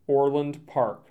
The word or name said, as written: Orland Park